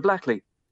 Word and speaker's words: Blackley: And the thing about Flatley in this film, by the way, Michael Flatley plays the character of Victor Blackley, which rhymes with Flatley.